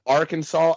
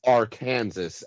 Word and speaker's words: Arkansas: Same thing, like, if you find yourself in Atlantic City, it's not Arkansas Avenue. It's Arkansas Avenue. That's what we call it.